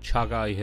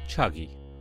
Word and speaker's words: Chagai: The Rekotik deposit is in a place called Chagai Hills, or as the locals pronounce it, Chagi.